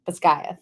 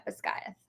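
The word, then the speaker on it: Basgiath: So we pronounce it the way that Rebecca Yarros interviewed and said she pronounced it, which is Bazgaith. – Yeah, Bazgaith.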